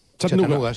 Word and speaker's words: Chattanooga: ¿Y se pronuncia así en Chattanooga? – Chattanooga, sí.